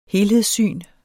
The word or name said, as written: helhedssyn